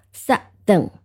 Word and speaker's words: southern: 那注意一个读音，就是 Southern，当我们读南的时候是 south，但是读成南的啊，形容词形式加上 ern，那么这个 ou 读成了啊，就是这个啊啊，往下压一下 duck 的这个同样的一个音啊， southern southern 啊，这个是一个读音上面要注意的。